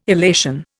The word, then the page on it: illation: Indian